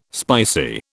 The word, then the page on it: spicy: British English